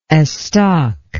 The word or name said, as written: estoc